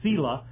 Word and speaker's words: Selah: Maybe I've been, you know, and I went, I looked up the pronunciation and all that, and it confirmed what I thought it was. It was Selah, but I thought Selah does really sound a lot more Hebrew than the way I'm doing it.